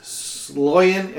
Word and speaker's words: Sloyan: The Defector is James Sloyan.